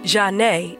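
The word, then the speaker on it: Zhané: And back in the 90s, the girl group Janae, and yes, the Z is silent, was one of the most underrated girl groups of all time.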